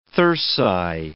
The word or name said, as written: thyrsi